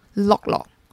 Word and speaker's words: lok lok: 那马来西亚的火锅啊叫做乐乐 lok lok lok。它是广东话“ 路” 的发音，它就有用火去快煮的意思。那很特别的是呢，不同于台湾的火锅店，大多都有店面，马来西亚的 lok lok 大多呢是路边餐车的小摊贩，在餐车附近呢摆出几张桌椅，就是客人的用餐区。